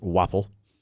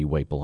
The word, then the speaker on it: Waple: The owner is Robert Waffle. He's the owner of the Jets Pizza in Mansfield. Maybe Waple. I apologize either way, Robert. – How do you spell it? – W-A-P-L-E. – Probably Waple.